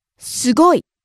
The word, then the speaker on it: すごい: Again, rising on the second syllable, ず, and coming down on the last syllable, い。 – 難しい。 – Next. – すごい。